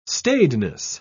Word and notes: staidness: British English

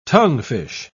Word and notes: tonguefish: British English

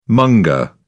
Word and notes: monger: British English

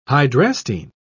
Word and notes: hydrastine: British English

